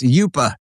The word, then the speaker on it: UPA: All right, this guy says the UPA is pronounced Yupa.